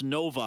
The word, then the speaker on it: Novi: It's spelled N-O-V-I. It's pronounced Novi. I said Novi when I asked him about it. It's Novi, and it's number six. So imagine the Roman numeral six is V-I. So N-O-V-I, number six, Michigan.